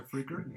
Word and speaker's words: Fricker: Mejor actriz de reparto, Brenda Fitch, eh, creo que se pronuncia Fricker.